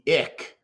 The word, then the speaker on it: IC: the the IC is uh, we pronounce it. I pronounce it the ick.